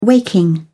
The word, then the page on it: waking: British English